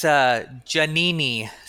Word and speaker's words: Giannini: So in Italy, it's Giannini. So yeah, I've been saying it wrong for the majority of my life.